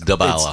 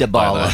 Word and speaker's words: Dybala: Dybala to Spurs. – It's not Dybala, it's Dybala. – It's Dybala.